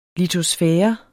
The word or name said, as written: litosfære